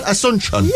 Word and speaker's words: Asunción: And the capital of Paraguay is Asunción. – Asunción. – Like Asuncion. Am I pronouncing that right? Asuncion. – Yeah, Asuncion.